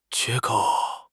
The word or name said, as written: 缺口